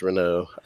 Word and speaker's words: Renault: It is pronounced Renault. I always forget that though. – The T is silent.